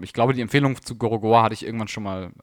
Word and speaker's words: Gorogoa: God of War? – Gorogoa.